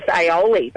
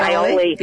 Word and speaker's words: aioli: But another one that we played around with recently is it actually makes a really nice aioli, making a finger lime. You know how to pronounce it. Aioli.